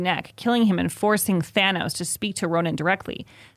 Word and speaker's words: Thanos: Thanos. It feels more Greek to me, like Thanos.